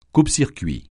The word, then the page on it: coupe-circuit: French